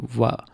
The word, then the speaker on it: Voila: So that's how probably pronounce it. Voila. You'll spell it V-O-I-L-A Norbert.